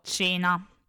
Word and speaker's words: coena: I dittonghi AE e OE si pronunciano E, come abbiamo detto anche nelle precedenti lezioni, quindi se troviamo la scritta caelum leggiamo cielo, come simile all'italiano. Se troviamo la scritta coena leggiamo cena ok?